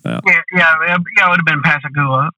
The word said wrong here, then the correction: Pascagoula: or, you know, I'm sure I butchered that. – Yeah, Pascagoula. – Pascagoula, but there were so many decoy factories there back in the day.